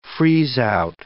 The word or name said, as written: freeze-out